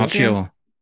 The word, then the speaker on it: Macchio: Maggio. – Macchio.